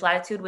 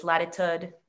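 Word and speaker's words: Latitud: And that's when we decided to build Latitude, and it's Latitude without an E at the end, because that's how you say it in Spanish. My American friends make fun of me and they say it's Latitude and I hate it.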